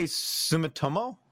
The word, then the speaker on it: Sumitomo: The Japanese wood production company, Sumoto... I don't know how you, how do you pronounce that? – Where are we looking? Where are we looking? I'd say Sumitomo? – Sumitomo Forestry Co.